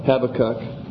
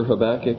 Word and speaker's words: Habakkuk: Some do pronounce it that way, Habakkuk. To me, I just can't quite live with that, so I prefer Habakkuk. I don't know that I've ever looked it up in a dictionary for the preferred pronunciation, but Habakkuk is good enough for me. The diacritical marks, Yeah, accent on the second syllable instead of the first.